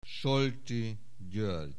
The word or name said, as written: Solti, György